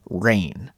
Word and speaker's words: reign: So first of all, let's talk about the word rain. Although it has the same pronunciation as the weather, it's spelt differently. R-E-I-G-N.